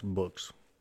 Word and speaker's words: Bugs: Before Oberlieutenant Zur C, that's equivalent to a lieutenant junior grade in the our Navy, Hans Helmut Bugs, pronounced Books, by the way, B-U-G-S as Books, was keeping his lookouts company on the conning tower, could sound the alarm.